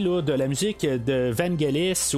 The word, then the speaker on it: Vangelis: Ben, on n'arrête pas de dire Vangelis, mais en même temps, je regarde sur Google on dit Vangelis. Euh, fait en tout cas, euh, j'imagine que Google a raison, hein, quelque part, euh, ce qu'on a plus élaboré d'essayer de savoir comment on prononce son nom.